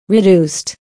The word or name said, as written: reduced